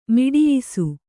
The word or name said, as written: ಮಡಿಯಿಸು